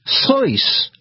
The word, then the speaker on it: Seuss: He actually pronounced his name Dr. Seuss. Yes, that's right. The German way.